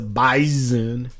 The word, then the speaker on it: Bison: It's pronounced the S like a Z is what they always tell me, so it's the Bison.